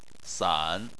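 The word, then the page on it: san: tone 3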